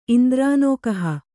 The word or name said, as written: ಇಂದ್ರಾನೋಕಹ